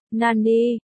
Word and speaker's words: nani: Nani, cái gì, o, nani, nani, trong phiên âm tiếng Trung đọc là, nani, đây là câu cảm thán mượn từ của tiếng Nhật, nani, cái gì, cách chương.